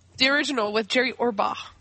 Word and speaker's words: Orbach: It's Jerry Orbach. And yes, you pronounce the H. – Orbach. – B-A-C-H, Jerry Orbach.